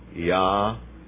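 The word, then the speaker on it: ya'al (יאל): Look in Hebrew, it's yod, aleph, lamed, ya'al. Pronunciation, ya'al.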